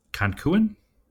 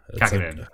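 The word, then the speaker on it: Kankkunen: Kankuan. Is that how you say that WRC driver's name? Kankuan? – No. I don't think that's how you pronounce it, but I can't. – Kakanen? – Yeah, Kakanen.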